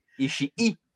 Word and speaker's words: Ishii: Ishii. Ishii, c'est vrai, hein, faut que je le prononce, ce là sinon il y du monde qui ne seront pas contents.